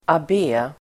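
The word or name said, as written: abbé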